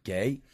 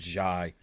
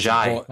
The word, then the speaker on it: Ghey: and apparently you don't pronounce her name Gay. Her name is pronounced Jai. – So it's Jai.